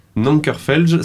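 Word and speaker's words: Nanker Phelge: Nankerfelge, ça s'écrit N-A-N-K-E-R, Nanker. Et Felge, P-H-E-L-G-E. E. Nankerfelge. Grand, grand groupe de rock.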